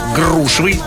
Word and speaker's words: грушевый: Ну, давайте так, как правильно произносить? Давай. Свекла, искра, грушевый, полшага, оптовый. Именно так и никак иначе.